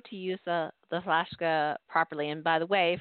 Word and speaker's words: Flaska: So you can go to flaska.us, which is spelled FLASKA, sort of like Alaska, but with an F, like as in Frank, .us.